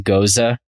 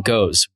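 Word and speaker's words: Gose: That I pronounce it Goza. We had this. You won't you won't tell me I'm wrong though. You think it, you're saying goes one syllable. It's both. I mean, it's it's goes Goza. Um, I've heard goose before.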